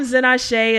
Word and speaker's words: Zenase: And thank you for joining us as As we wrap up this episode, so I am Zenashe. It's spelled Z-E-N-A-S-E.